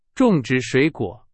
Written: 种植

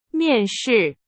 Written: 面试

